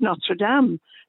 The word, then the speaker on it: Notre Dame: I mean, uh, you talk about the American football team; they can't even pronounce their blooming name properly. Uh, it's Notre Dame. Uh, but I mean, what do you expect from from Americans? – Well, that's the no, French. That's I mean, the French, though. Is it? it's it's it's pronounced different though in the states for the um, for yeah. the university, isn't yeah.